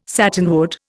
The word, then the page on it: satinwood: Indian